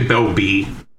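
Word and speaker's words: Belbe: Is it pronounced Belby the Corrupted Observer, or Belbay? – I would say Belby.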